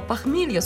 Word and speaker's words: похмелье: Слово «похмелье» с ударением на последний слог.